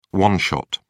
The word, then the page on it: one-shot: American English